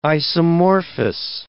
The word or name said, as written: isomorphous